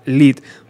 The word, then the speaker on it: lead: Page sustituyó el globo por el Zeppelin y el plomo, que en inglés se pronuncia lead, por LED.